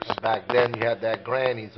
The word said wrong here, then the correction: Grandy's: on the corner. – They were Grandy's, if you want to pronounce it the right way.